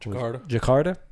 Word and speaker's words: Jakarta: Episode 2 kicks off with a lengthy scene set in 2003, Jakarta? How do you say that? – Jakarta? – Jakarta. Terrible.